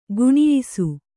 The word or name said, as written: ಗುಣಿಯಿಸು